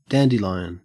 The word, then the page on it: dandelion: Australian English